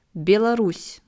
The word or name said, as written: Belarus